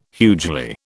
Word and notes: hugely: British English

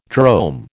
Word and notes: drome: American English

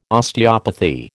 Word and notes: Osteopathy: American English

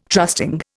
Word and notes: trusting: Indian

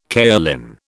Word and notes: kaolin: British English

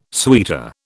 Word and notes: suitor: British English

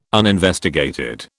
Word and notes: uninvestigated: British English

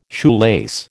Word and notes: shoelace: American English

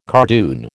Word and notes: cardoon: American English

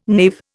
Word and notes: knave: Indian

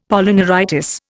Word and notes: Polyneuritis: Indian

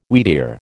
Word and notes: wheatear: American English

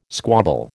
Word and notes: squabble: American English

